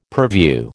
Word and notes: purview: American English

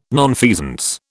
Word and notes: nonfeasance: British English

